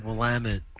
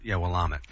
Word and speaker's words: Willamette: From the help me out with my bushy Willamette. – We call these guys. Remember? – Willamette. Actually, yeah, you're right. I called Penner Ash to get the pronunciation, and I still mess it up.